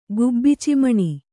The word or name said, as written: ಗುಬ್ಬಿಚಿಮಣಿ